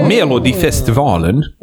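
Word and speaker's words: Melodifestivalen: The Mamas got their breakthrough overnight when participating in Melodi Festival in 2019. – Which is actually the pre-competition for Eurovision in Sweden. – Okay. – Melodi Festivalen.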